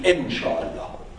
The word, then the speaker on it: inshallah: First of all, inshallah, if pronounced in Persian, will be inshallah. That's not in. That ye is additional thing to make it pronounceable for foreigners. When Ahmed will say inshallah, he will not say in.